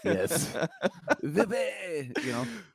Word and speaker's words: wypij: It ends that way, and it would actually be pronounced in Poland as Vippy.